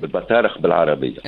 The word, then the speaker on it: البطارخ: اي ما هما يجيو الوقت هذا بالبيض نتاعهم البطارخ ولا البطارق كيفاش النطق؟ – البطارق البطارخ بالعربيه، البطارخ بالعربيه، وبالدارجه نتاعنا كيفاش رديناها؟